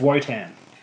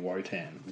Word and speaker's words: Wotan: It is spelled Wotan, but I'm pretty sure they say wonton or something. They say like a V. – You know a wonton is something in like a soup with noodles. – I know. It's spelled Wotan though, so it's Wotan. So I think they say Wotan. They say it with like a V sound.